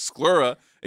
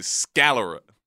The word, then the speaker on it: sclera: You're also wrong because you were talking about a part in the eye that is pronounced sclera, and you kept saying scalera.